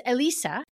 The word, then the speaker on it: Elisa: It's Elisa. Which to me is so easy. It's Lisa with an F in front of it, you know.